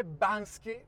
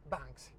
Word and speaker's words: Banksy: Però attenzione, perché va pronunciato bene. Non è Banksy, come tutti si ostinano a pronunciarlo, è Banksy.